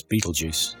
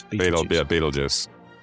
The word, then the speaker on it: Betelgeuse: I've said it wrong. – That's Betelgeuse. It's Betelgeuse. – Betelgeuse. – Is that how you spell it? That's not how the movie spell. Is that Betelgeuse? – That's how you pronounce that word.